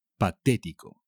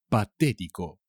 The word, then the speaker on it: patético: Patético. Patético. Con un acento en la E.